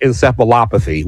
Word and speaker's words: encephalopathy: I mean, there are a lot of guys suffering from, and I'm going to hope I say this right, CTE, chronic traumatic encephalopathy. Ooh, I said it right.